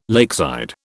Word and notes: lakeside: British English

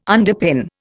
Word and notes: underpin: Indian

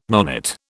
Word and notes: nonet: British English

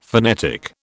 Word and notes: phonetic: British English

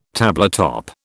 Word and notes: tabletop: British English

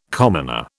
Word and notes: commoner: British English

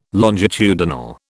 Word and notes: longitudinal: British English

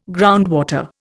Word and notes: groundwater: Indian

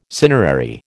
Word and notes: cinerary: American English

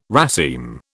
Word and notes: raceme: British English

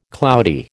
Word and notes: cloudy: American English

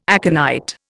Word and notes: aconite: Indian

